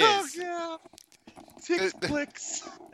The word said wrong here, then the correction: Tick Blitz: That's how I knew that he was saying it wrong. Because it's Tick Blitz. It's Tick Blitz.